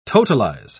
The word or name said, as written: totalize